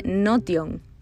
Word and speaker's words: Notion: Se escribe Notion y se pronuncia Notion es una herramienta para organizar todo tu contenido.